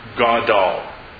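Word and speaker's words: Gadol (גָּדַל): I'm going to spell it out. G-A-D-A-L. And it's pronounced Gadol.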